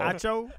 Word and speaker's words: Acho: You turned him into Gacho. – You turned him into Devon Gacho. Emmanuel Acho. – Acho? – Acho.